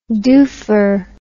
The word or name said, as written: doofer